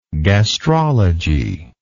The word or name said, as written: gastrology